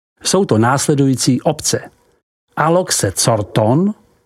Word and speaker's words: Corton: Mezi víny převládají vína barvy červené, vyrobená z odrůdy Pinot Noir, pouze nedosahující s výjimkou vína Corton, vyslov Corton, těch nejvyšších jakostních statutů pro burgundské vinařství.